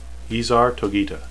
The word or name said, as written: easar